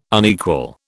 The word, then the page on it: unequal: British English